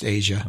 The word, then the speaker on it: Aja: There's a documentary I watched about the Asia album, AJA. I think it's pronounced Asia. Yeah, I think so too.